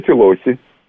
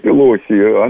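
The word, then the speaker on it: Pelosi: Нэнси Пелоси. Она итальянского происхождения, поэтому так странно ее фамилия звучит, Пелоси, а не Пелоси, как очень часто ее и не произносят.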